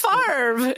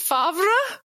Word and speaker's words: Favre: I don't think I thought it was Favre. It's Favre. – It's F-A-V-R-E.